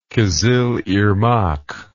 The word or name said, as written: kizilirmak